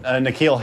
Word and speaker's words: N'Keal: Hakeel Harry? – Oh, Nikhil Harry. – Nikhil? – Yeah, I'm sorry, Nikhil. I'm sorry, Nikhil. – Yeah. – Nikhil in this case is spelled N apostrophe K-E-A-L.